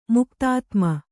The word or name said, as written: ಮುಕ್ತಾದಾಮ